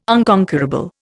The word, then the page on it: unconquerable: Indian